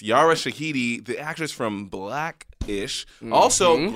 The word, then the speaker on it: Yara Shahidi: Yara Shahidi.